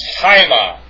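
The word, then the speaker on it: haima (αἷμα): It's pronounced haima. The alpha iota, diphthong is pronounced I, not A, it's I. So it's haima, haimatas, meaning blood.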